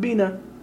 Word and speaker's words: Bena: I don't know what Leech Lake, what that reservation, I mean, is it B-E-N-A? Pronounce that. – Bina.